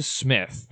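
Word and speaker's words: Psmith: You'll be confused because the Smith has a P at the beginning of it. I mispronounced that for many years, just like the characters in the book mispronounce it.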